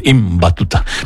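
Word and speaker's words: Ibn Battuta: Hay que pronunciar bien, Iván Batuta.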